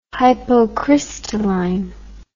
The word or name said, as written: hypocrystalline